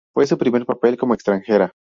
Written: extranjera

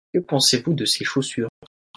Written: chaussures

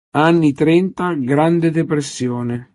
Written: depressione

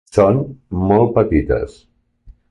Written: petites

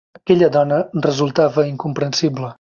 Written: resultava

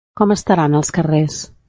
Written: estaran

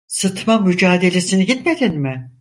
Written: gitmedin

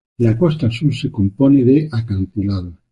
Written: acantilados

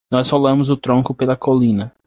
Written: tronco

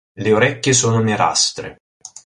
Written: orecchie

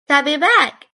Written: coming back

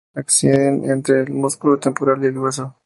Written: músculo

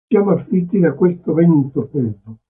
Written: freddo

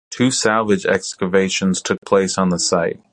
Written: excavations

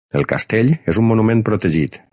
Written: protegit

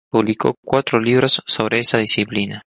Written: disciplina

